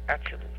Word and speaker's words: Atziluth: The first world is Atzilut, spelled A, T as in Thomas, Z as in zebra, I, L-U-T-H. And I want you to write this directly below fire. A-T-Z-I-L-U-T-H, and it's pronounced Atzilut. The T-H is a hard T.